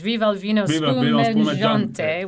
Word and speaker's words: Viva il vino spumeggiante: After the Easter Sunday Mass, Turiddu leaves leads the villagers in a rousing drinking song, and you should pronounce this, Viva il vino spumeggiante, which I'm sure you've heard before, before being confronted by Alfio.